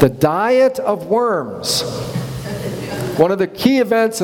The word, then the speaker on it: Worms: So Charles V called a diet, coming together in the German city of Worms, W-U-R-M-Z usually, but it's pronounced like Worms.